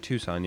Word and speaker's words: Tucson: Tucson is that how you pronounce it? My apologies. – No, no, that's right. – Tucson, yeah.